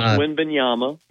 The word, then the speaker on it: Wembanyama: Coach, what can you tell us about the kid from France, Victor Wembanyama? I'm sure I just butchered his name. – That's close. Wembanyama. Yeah, that's close.